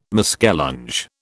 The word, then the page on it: muskellunge: British English